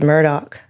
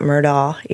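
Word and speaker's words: Murdaugh: and it's Murdoch, not Murdaw, even though it ends in...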